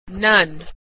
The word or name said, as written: none